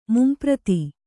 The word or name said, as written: ಮುಂಪ್ರತಿ